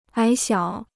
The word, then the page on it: 矮小: ǎi xiǎo